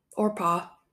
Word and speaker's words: Orpah: So then it was just the 3 girls and Oprah, Orpah, not Oprah, it's spelled different.